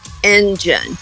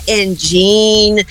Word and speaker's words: engine: Não é engine, não é engine, pelo amor de Deus, presta atenção. São duas sílabas. Você olhando no papel dá a impressão que são três, mas são duas sílabas. Engine, engine, engine, ok?